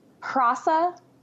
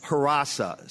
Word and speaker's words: Hrossa: So the first one is what? Say it again. – Hrasa. – H-R-O-S-S-A. – H-R-O-S-S, yes. – Hrasa.